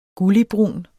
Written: gulligbrun

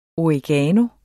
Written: oregano

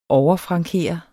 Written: overfrankere

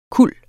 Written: kuld

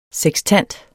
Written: sekstant

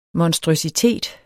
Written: monstrøsitet

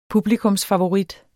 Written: publikumsfavorit

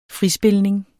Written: frispilning

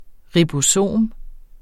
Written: ribosom

